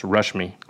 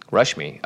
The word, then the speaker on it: Rashmi: I'm told by Shivam that this is actually pronounced Rashmi. – Rashmi. Okay, yeah. It's the A is not as Western, I guess.